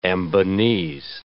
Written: Ambonese

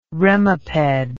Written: remiped

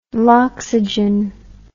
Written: loxygen

